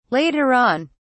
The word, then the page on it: later on: British English